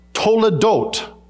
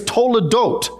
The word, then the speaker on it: toledot: And the clue has to do with a weird little Hebrew word, which I pronounce, toledot. Toledot. It's not a candy that you unwrap and take. That's Toblerone. That's something totally different. This is toledot.